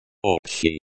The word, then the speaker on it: Общий: Общий. Pronounced общий and feminine form. Общая. Pronounced общая. Pronunciation. Общий. Общий, the sh sound is similar to the sh in ship, but slightly softer.